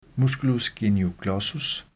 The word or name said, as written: m. genioglossus